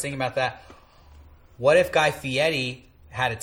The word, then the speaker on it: Fieri: Guy Fieri.